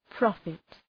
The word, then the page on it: profit: 'prɒfıt